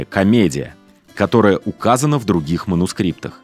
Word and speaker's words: комедия: Кстати, Данте называл ее «комедия» с ударением на «и», на греческий манер.